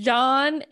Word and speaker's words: Jean: Je- Jean is pronounced like John, but with a French accent.